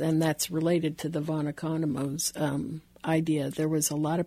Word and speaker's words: Economo: And unfortunately, von Economo's disease was one of them. However, it was taught to me. – Was von Economo's, did I say that right? – Economo, sure.